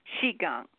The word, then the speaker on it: Qigong: No, don't try to say it like a Q. Say it like C-H. Qigong. Because we look at the sound. We're trying to pronounce a Q. Q-I. It's Q-I. – Oh. – But it's pronounced C-H-I. Okay. But in Chinese, they don't pronounce the Q. They pronounce the Q as a C-H sound. So it's Q-I. G-O-N-G, Qigong.